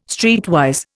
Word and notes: streetwise: Indian

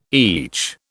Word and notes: each: British English

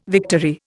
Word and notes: victory: Indian

